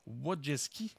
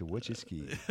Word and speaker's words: Wojcicki: Et en fait, c'est reconnu, la dirigeante Susan Wojcicki, et c'est comme ça qu'on prononce son nom, parce que j'ai regardé juste ça. – T'es bon. Tout le monde répète, Wojcicki. Wojcicki.